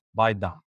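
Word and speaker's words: Baidam: So the name of our company is Baydom, but the correct pronunciation of Baydom is actually pronounced Baydom.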